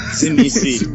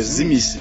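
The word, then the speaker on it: Tzimisce: Eu sou jogo de Sminci, tá? – Zimice. Esse aí tem a pronúncia no livro, terceira edição realizada. É Zimice. – Zimice? – Esquece o T. Esquece o T, Zimice.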